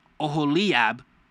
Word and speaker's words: Oholiab: Oholiab, you guys try it, is spelled O-H-O-L-I-A-B.